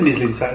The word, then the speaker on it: Mislintat: Well, how do you say that? – Sven Mislintat, isn't it? – Sven Mislintat from Borussia Dortmund.